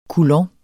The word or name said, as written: coulomb